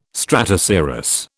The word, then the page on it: stratocirrus: British English